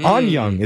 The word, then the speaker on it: annyeong: Annyeong is how you say hello in Korean.